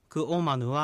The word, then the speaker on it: クオマヌ: クオマヌはっていうふうに発音しているので注意してください。